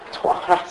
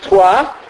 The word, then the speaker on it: Troyes: Troyes. – See, I can't pronounce it. That's why I asked you to say it. T-R-O-Y-E-S, right? Troyes, I think it's pronounced.